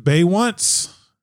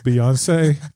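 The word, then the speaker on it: Beyoncé: Was it Baywatch? I think it's pronounced Beyonce.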